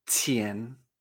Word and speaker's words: qian: El pinyin siempre lo veremos I-A-N, pero nosotros mentalmente tenemos que hacer ese cambio y pronunciarlo con E, yen. Por ejemplo, dinero en chino se escribe en pinyin Q-I-A-N en segundo tono. Se pronuncia chien. Qian.